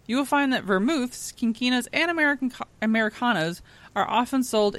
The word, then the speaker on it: quinquinas: I already forgot how to say the other word. Quinquinas? Yep.